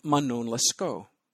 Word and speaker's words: Manon Lescaut: 9 years later, Puccini weighed in with his own version, which he called Manon Lescaut. By the way, you pronounce the final N when talking about Puccini's opera, Manon Lescaut. That's Italian style.